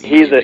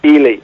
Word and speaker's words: Ely: Guys, I had a subscriber to Bear Hunting Magazine from up near, I believe it's Ely, Minnesota, mm-hmm. if I'm saying that right. – Yeah, and I think it's Ely, yeah. – Ely, yeah.